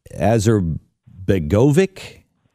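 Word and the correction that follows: Alija Izetbegović: I butchered it. – Yes, Alijah Izbegovic.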